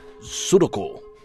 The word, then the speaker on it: sudoku: Also, you may receive Will Short's Little Black Book of Sudoku. – No, it's not pronounced like that. – Oh, yeah. Sudoku.